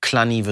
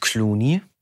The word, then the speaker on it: Cluny: Aus Clunny wird Clooney, der kleine Junge.